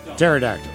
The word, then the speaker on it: pterodactyl: Pterodactyl. Because the P is silent.